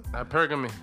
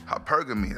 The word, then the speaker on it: hypergamy: Hypergamy. – Hypergamy. That's how you say it.